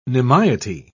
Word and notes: nimiety: British English

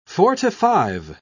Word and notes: forty-five: British English

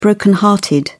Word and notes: broken hearted: British English

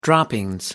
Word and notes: droppings: American English

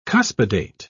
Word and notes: cuspidate: British English

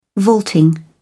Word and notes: vaulting: British English

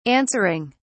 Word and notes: answering: British English

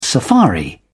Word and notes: safari: British English

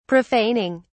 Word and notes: profaning: British English

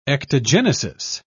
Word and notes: ectogenesis: British English

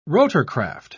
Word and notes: rotorcraft: British English

